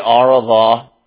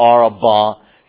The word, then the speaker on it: Arava: And the one riding in the desert flat, have you ever heard of the Arava? Or maybe you hear it pronounced with the V with a B, the Arava.